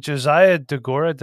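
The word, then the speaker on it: Josiah Deguara: And now Nate's number one tight end on the season coming in, Josiah DeGora. Did I say that right? – I believe so.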